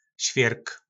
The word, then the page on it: Svjyrk: Po našimu